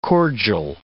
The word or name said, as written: cordial